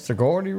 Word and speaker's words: Sigourney: Sigourney. Am I saying it correct? – Sigourney Weaver. Yes.